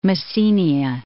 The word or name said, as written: Messenia